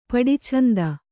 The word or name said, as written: ಪಡಿಛಂದ